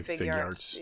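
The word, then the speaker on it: Figuarts: We're kind of old pros with the Figyarts. I've heard it pronounced a million different ways. – I say Figyarts. Yeah. – So. – If they made it one word, I'll say it as one word.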